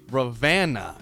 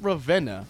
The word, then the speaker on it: Ravenna: apparently the locals say Ravenna, not Ravenna.